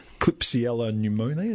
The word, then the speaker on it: Klebsiella pneumoniae: Klebsiella pneumoniae. Is that how you say it? – Pretty close.